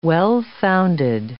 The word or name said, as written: well-founded